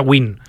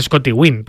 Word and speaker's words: Nguyen: Se pronuncia W-I-N, pero se pronuncia Win. Sí. Por ejemplo, Scotty Nguyen, que ha sido campeón del mundo, que es el Nguyen más famoso que hay, sí, es Scotty Win, porque se pronuncia Win. Pero se escribe así, N-G-U. Y-E-N, Nguyen.